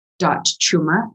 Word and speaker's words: Chuma: It's my first name dot last name, so Sandra chuma, and my last name is spelled C-H-U-M-A.